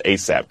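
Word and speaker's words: A$AP: And you can't atone by that by simply trying to get, it's pronounced ASAP, Chris, trying to get ASAP Rocky out of jail.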